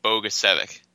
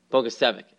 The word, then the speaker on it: Bogusevic: How do you say it? What did we agree on before the show? – We agreed on Bogusevic. – Bogusevic, that's right. Bogusevic. Brian Bogusevic. Almost sounds like it looks.